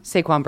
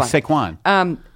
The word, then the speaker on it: Saquon: Saquon Barkley. Yeah, Saquon. – Okay, Saquon.